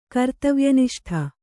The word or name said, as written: ಕರ್ತವ್ಯನಿಷ್ಠ